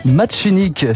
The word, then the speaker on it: Martinique: Quant à l'origine, certains évoquent Marseille, d'autres le Québec, le Maghreb, une piste aussi, la Martinique, qui se prononce bien machinique, chez les Martiniquais.